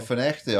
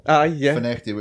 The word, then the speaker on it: Findochty: You got Finnechty up in Murray as well. Finnechty, which is spelled Findochty.